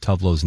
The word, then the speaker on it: Tove Lo: I was today years old, and Taylor just told me the actual proper pronunciation of Tove Lo's name.